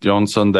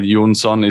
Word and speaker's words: Jonsson: There's Nick Jonsson there, N-I-C-K-J-O-N-S-S-O-N. So that's, you got it right. Jonsson is the Swedish pronunciation of my last name.